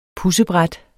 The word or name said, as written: pudsebræt